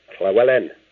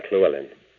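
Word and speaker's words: Llewellyn: And I was saying, you pronounced it wrong. The Wellen bit's fine, but the Hle is a crime. Clewellyn is a Welsh name. And in Wales, you pronounce la, Cle, if there's a double L in it.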